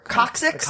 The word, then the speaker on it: coccyx: I'm not really sure how do you pronounce this. I've seen this word before. Coccyx? – Yeah, that's right.